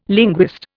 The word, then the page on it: linguist: Indian